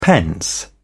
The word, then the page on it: pence: British English